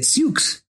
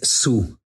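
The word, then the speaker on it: Sioux: Se escribe Siux, y mucha gente equivocadamente les dice Siux, pero la pronunciación correcta es Su.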